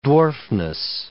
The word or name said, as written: dwarfness